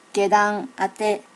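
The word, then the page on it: gedanate: native Japanese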